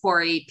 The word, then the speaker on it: Pori: I'm on Twitter and Instagram at Bella Pori, which Bella spelled like Twilight, Pori, P-O-R-I.